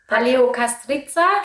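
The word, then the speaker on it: Paleokastritsa: weil es war du hast es auch vorhin mit, du hast es vorhin viel besser ausgesprochen. Paleo Castriza? – Genau.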